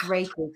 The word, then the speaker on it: Reiki: You did say it right, is Reiki, because a lot of people call it Reiki. – Just too many vowels in there for me. – It is Reiki. You got it spot on.